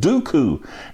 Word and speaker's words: Duqu: Now, basically what this is about is there is a zero-day exploit called Dooku. Now, it's like Count Dooku on Star Wars, except that it's spelled different. It's spelled D-U-Q-U.